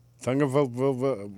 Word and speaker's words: Tagovailoa: Why did you put an R in there? – I don't know. Tagovailoa? – Repeat after me. I'll break it down phonetically. – All right. – Tagovailoa. – Yeah, no. – What, you can't say Loa? – You had it until the last one.